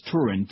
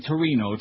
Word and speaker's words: Turin: It's Turin, T-U-R-I-N. – Yeah. – But NBC, including that promo we just had a little bit ago, they keep saying Torino.